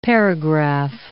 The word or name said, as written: paragraph